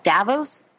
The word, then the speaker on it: Davos: is it Davos? – Yeah, that's that's actually I think exactly how it's pronounced.